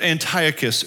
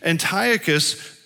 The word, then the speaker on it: Antiochus: Uh, uh, uh, his, his, name is pronounced three or four different ways, but Antiochus is how I'll say it.